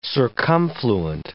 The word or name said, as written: circumfluent